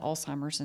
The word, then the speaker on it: Alzheimer's: And I know a lot about Alzheimer's and helped there.